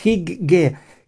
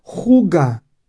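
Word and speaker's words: hygge: Y aquí hay una palabra, creo que es del danés, que pone HIGG y una E, leído así Higge, que se pronuncia juga, y dice la felicidad en las pequeñas cosas, la felicidad o el placer.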